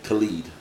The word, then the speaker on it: Khalid: And Khaled was 10. Khalid, I'm sorry.